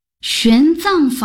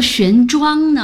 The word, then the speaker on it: 玄奘: 玄奘法师？我一直以为读作玄奘呢。 – 玄奘的奘上面是强壮的壮的繁体字字形，下面是大小的大，上下合起来是壮大。 – 是的，藏的意思正是壮大，用于人名如唐代的玄奘。